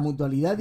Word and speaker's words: Mutualidad: Y asesor de la Mutualidad de Ingenieros de Cataluña. ¿Eh? Esta vez lo he dicho bien. – Sí, lo fui.